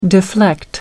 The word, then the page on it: deflect: American English